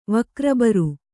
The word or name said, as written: ವಕ್ರಬರು